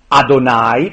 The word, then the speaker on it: Adonai: Yahweh, Adonai, pronunciano gli ebrei, è il nome proprio.